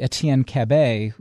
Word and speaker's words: Etienne Cabet: This is where we'll eventually get to Etienne Cabet, is that how he pronounces his name, by the way? – Yeah, that's right. That's absolutely right.